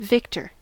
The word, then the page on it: victor: American English